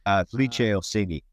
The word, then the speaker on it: Felice Orsini: I hope I'm pronouncing the name correctly, Felice Orsini. – Felice Orsini, yes.